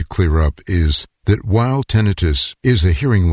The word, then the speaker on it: tinnitus: And lastly, I do want to mention as a contributor to hearing loss, tinnitus, or tinnitus as some of you may pronounce it.